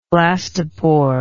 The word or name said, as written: blastoporal